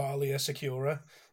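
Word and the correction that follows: Aayla Secura: It's Ayla Secura, isn't it? – That one.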